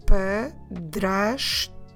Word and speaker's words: padrasto: The word for stepfather is the word padrasto. It's a bit long word, I will repeat it. The stepfather is o padrasto. Let me spell it for you in Portuguese. Padrasto. P-A-D-R-A-S-T-O. Padrasto. So now I think you're getting into the logic of this word my.